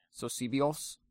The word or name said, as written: Sosibios